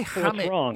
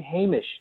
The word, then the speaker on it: Hamish: The way you pronounce the name Hamish... – Yeah. – You say Hamish. You say Hamish all the time, and I'm just going, no, guys, please.